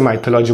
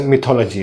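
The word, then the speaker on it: mythology: अगर आप मेरे पुराने वीडियो जाके देखोगे तो वहाँ पे मैंने इसे माइथोलॉजी बोला है, बट एक्चुअली इसका प्रोनाउंसिएशन मिथोलॉजी है।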